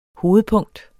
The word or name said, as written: hovedpunkt